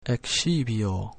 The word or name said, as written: excipio